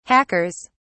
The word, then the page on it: hackers: British English